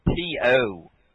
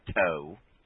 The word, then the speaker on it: T.O.: I think you mean T.O., which is short for Terrell Owens, not Toe.